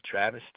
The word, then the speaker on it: Travis: Travis, T-R-A-V-I-S. – Okay. – Johnson, J-O-H-N-S-O-N.